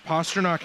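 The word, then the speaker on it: Pastrňák: So Pastrnak has 28 goals. Yes, I said that correctly.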